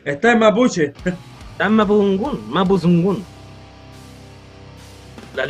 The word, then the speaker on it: Mapuzungun: ¿En la pronunciación oficial es Mapuzungun, no Mapuzungun. Así es. Mapuzungun.